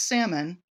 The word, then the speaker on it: Salmon: Their name is spelled S-A-L-M-O-N, which most people pronounce salmon, like the fish, but the family members all use the L, so it is actually Salmon, not salmon.